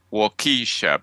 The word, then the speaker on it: Waukesha: But this one, which I'm hoping our listener may be able to help with this mystery, because she's got in there, and it's spelled W A U K E S H A. I thought it was Waukesha, but anyway, I've looked it up and apparently it's pronounced Waukesha.